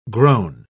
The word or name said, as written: groan